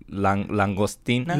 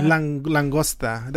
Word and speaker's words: langosta: I was gonna say like langostina. – Langosta. That's langosta.